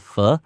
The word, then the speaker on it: pho: The most quintessential Vietnamese food, and we see it a lot in restaurants here in the States now, is what's called pho, which is how you actually pronounce that, spelled P-H-O.